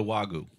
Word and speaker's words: Wagyu: Yeah, no Wagyu. – Did I say that right? – Good job.